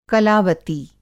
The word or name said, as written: कलावती